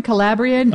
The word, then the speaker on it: Calabrian: Am I saying it right, Kevin? Calabrian? – Oh, that's how I would say it, yeah.